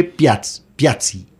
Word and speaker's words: Piazzi: Fue el primero descubierto en 1801 por un monje italiano que se llamaba Giuseppe Piazza. Yo siempre se me olvida. – Yo tampoco sé italiano. Cómo se pronuncia. – Giuseppe Piazzi.